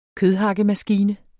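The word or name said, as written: KØDHAKKEMASKINE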